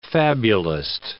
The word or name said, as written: fabulist